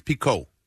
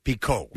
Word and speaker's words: Picault: Picault. – Yeah. – Okay. – That's a cool name. – P-I-C-A-U-L-T. So like a Renault car. – Yes. – I remember it was spelled Renault. – Fafa Picault.